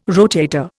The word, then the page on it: rotator: Indian